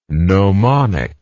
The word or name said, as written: gnomonically